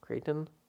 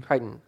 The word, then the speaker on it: Crichton: I didn't watch the old movie, but I mean, so far it looks like, you know, Michael Crichton. – Crichton.